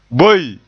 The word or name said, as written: boe